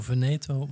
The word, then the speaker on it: Veneto: Heb ik de regio Veneto wel goed uitgesproken? – Klonk prima.